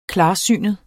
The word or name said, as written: klarsynet